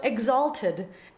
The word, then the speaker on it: exalted: But the word is still exalted, and the stress is still on the second syllable and not on ted.